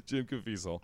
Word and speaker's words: Caviezel: My favorite movie of all time is the newer Count of Monte Cristo with Guy Pearce and Justin Casvail? Jesus, he played Jesus in The Passion of the Christ. His last name is impronounceable. Okay, there you go again. You're doing great. You're already correcting me. Yes, Jim Caviezel.